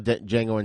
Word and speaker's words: Django: He's been in Enter the Ninja, Die Hard 2, Django Unchained, which I remember seeing him when I saw Django Unchained in the theater, and he was sitting at the bar with Django. I was like, oh, my God, that's Franco Nero. Oh, shit. It's the D is silent.